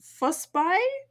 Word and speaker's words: Forsberg: Also, yeah, apparently Forsberg is pronounced Fussby? Something like that, which is very different from how we've been pronouncing it.